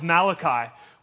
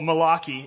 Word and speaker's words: Malachi: And then we're going to get into the book of Malachi, or Malachi, if you want to pronounce it incorrectly,